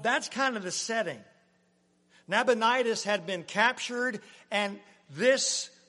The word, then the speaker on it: Nabonidus: Belshazzar is actually the son of the king named Nabonidus. Boy, I might have butchered that one, but that's close. It's like a disease, Nabonidus. Nabonidus, that's better.